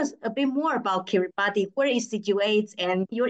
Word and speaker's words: Kiribati: The name of the country is written uh, Kiribati with TI at the end, but the pronunciation is Kiribati,